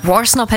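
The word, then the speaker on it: Worsnop: Gustavo Maximo Rock, Dark Thoughts, The Funeral Portrait featuring Danny Warsnop. Hast du das richtig ausgesprochen? Warsnop, ne? – Warsnop hätte ich jetzt auch gesagt, aber ich finde diesen Anfang so mega.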